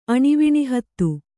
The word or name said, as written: ಅಣಿವಿಣಿಹತ್ತು